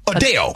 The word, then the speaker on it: Adele: Yeah, like Dale. – See? – Yeah. – It's not Adele. So if you got to walk around, oh, I love Adele, you're saying it wrong.